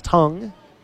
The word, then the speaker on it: Teng: Last name is spelled T-E-N-G. He just recently told media reporters that his last name is pronounced Tung.